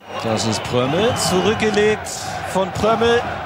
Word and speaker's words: Prömel: Er wird ja mit einem Ö und einem M geschrieben, da sagt man dann Prömel. Komischerweise gibt es ein Agreement, ein unausgesprochenes zwischen vielen, vielen Kommentatoren, die Spiele von Union Berlin kommentieren, und die sagen Prömel. Obwohl er doch mit einem Ö und einem M geschrieben wird, sagen die Prömel.